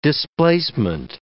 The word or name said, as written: displacement